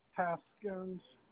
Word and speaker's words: Haskins: Haskins, HASKINS.